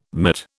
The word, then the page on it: mitt: British English